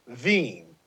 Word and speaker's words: Wien: Vienna is actually W-I-E-N, pronounced Veen. So... then they have the Wiener Schnitzel, but it's actually W-I-E-N-E-R, which we would say is Wiener Schnitzel.